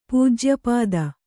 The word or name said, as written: ಪೂಜ್ಯಪದ